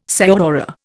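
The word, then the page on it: señora: Indian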